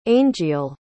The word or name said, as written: aingeal